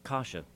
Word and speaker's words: Kasha: So, do you say is it pronounced Kasha? – Kasha, correct.